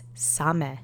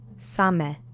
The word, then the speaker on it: same: The first word is same, which means only or alone. The accent is on the first syllable. Let's say it together three times. Same.